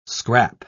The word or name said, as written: scrap